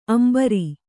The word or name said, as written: ಅಂಬರಿ